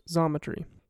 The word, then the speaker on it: Xometry: This is how I came across a platform called Xometry, spelled with an X but pronounced Zometry.